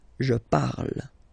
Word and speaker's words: parle: and then the way to pronounce it is je parle, parle. So remember, we put it, okay, but then we don't really pronounce it, je parle, okay.